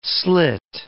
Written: slit

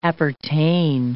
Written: appertain

